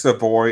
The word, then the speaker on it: Savoy: April's son, I believe it's pronounced Savoy. It's S-A-V-O-Y.